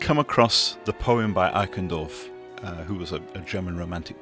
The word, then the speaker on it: Eichendorff: Eichendorff. – Okay, Eichendorff.